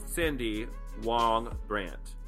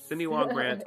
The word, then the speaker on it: Cindy Wong Brandt: You are Cindy Wong Brandt.